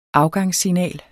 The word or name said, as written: afgangssignal